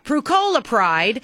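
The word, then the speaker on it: Prucalopride: Prucolopride is a prescription laxative used for chronic constipation.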